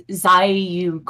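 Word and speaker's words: Zairyu: It's Zaryu card. – So I'm saying it, am I like enunciating the I with it? – It sounds like Z-A-I, Zaryu.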